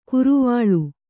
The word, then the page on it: ಕರ್ವಾಳೆ: karvāḷe